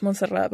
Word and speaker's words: Montserrat: And so, like, a lot of them like are curious on how to say it, and then I'll just say like, oh, it's Montserrat, but I go by Monse.